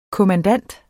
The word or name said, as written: kommandant